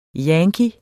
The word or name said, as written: yankee